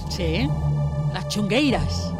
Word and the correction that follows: Tanxugueiras: ¿Lo he dicho bien? – Tanxugueiras. – Tanxugueiras. Me ha salido un chunguitos en gallego.